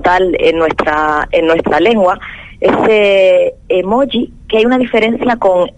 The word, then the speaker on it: emoji: porque la J castellana me cuesta muchísimo, como yo creo que a la mayoría de los canarios, y decir también, yo diría emoji, entonces suena un poco raro, así me van a permitir que lo diga como emoji, y ya decía, escribirla en cursiva si la pronunciamos así.